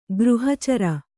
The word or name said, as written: ಗೃಹಚರ